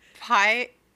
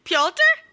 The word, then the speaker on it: Pyotr: Is it Pyotr? – Is it Pyotr? – Pyotr? – Like Peter almost, but it's like Pyotr?